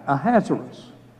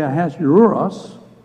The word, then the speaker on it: Ahasuerus: I just pronounce it Ahasuerus. That is one of the acceptable pronunciations. There are others who say Ahasuerus, and others who say Ahasuerus. But I'm going to use Ahasuerus, and that's the easiest for me to pronounce.